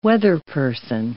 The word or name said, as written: weatherperson